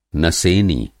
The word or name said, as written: नसेनी